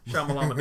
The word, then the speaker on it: Shyamalan: How you say it? M. Night? – M. Night. Shyamalan Ding Dong.